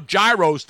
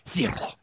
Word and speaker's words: gyros: There's no H in it, but it has an H in it. It's G-Y-R-O-S, but it's pronounced hero.